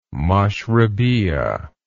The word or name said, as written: mashrebeeyah